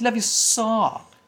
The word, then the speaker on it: Leviosa: It's not Leviosa, it's Leviosa.